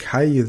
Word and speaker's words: calle: Und denk dran, wenn du dann das Wort calle siehst, Doppel-L wird wie J ausgesprochen im Spanischen. Das kennen wir von Mallorca.